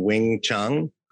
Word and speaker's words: Wing Chun: Think Like a Mind Reader, Perfect Recall, Learn Like a Mind Reader, and Wing Chun.